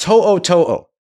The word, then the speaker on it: To'o To'o: Alabama's Henry, it's pronounced To'o To'o. T-O apostrophe O, T-O apostrophe O.